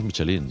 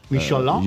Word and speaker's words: Michelin: A ver, aquí en la Península Ibérica hacemos Michelin. – Sí, claro. – Claro, pero la realidad, tú eres un chef internacional. – Ya, pero ¿tú cómo lo pronuncias? – Me cuesta ser tan tontorraco, ¿no? Michelin. – Michelin. – Yo digo Michelin.